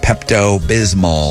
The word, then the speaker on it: Pepto-Bismol: That's Pepto-Bismol. – Yeah, that tastes like chalk. – I'm not sure if I'm saying that right. Pepto-Bismol. – Sounds right.